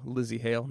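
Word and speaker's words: Lzzy Hale: Just yesterday on her Instagram account, which you can find at official Lizzie Hale. Now, Lizzie, for those of you who don't know, is spelled with no I. So it's LZZYHALE.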